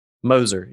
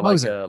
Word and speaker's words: Moser: Is it Mosier or Moser? – Moser. Yeah. Moser. Like a bulldozer. Moser.